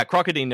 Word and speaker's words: Crocodine: Crocodine. Is that how you say it? – Yeah, I think it's Crocodine, just based on the way it was in Japanese. But yeah, Crocodine in my head is Crocodine.